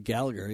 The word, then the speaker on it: Gallagher: Gallagher, yeah.